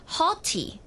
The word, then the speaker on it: haughty: 那除此之外呢，也有一个比较少见一点的词啊，它呢就读作 haughty。 – Haughty 有点像 naughty 一样的、它的拼法也是差不多的。